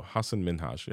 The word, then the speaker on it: Hasan Minhaj: And if you may or may not have heard, Hasan Minhaj or Hasan, how do I say his name? Hasan Minhaj. Or no, Hasan Minhaj.